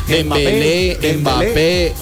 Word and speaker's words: Dembélé: Los apellidos, todos terminan en E con acento en la E. – Ajá. – Koundé, Dembélé, Ousmane Doudé.